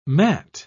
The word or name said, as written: matt